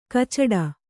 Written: ಕೈಚೀಟು

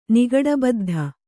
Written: ನಿಗಡಬದ್ಧ

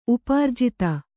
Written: ಉಪಾರ್ಜಿತ

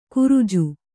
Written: ಕುರಿಜು

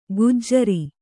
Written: ಗುಜರಿ

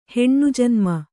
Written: ಹೆಣ್ಣುಜನ್ಮ

